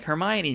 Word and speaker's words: Hermione: And then you go to watch the movie for the first time, and they're like, Hermione. And you're like, who's that? What character from the book is? Whoa. That's how you say that? I said it wrong the whole time.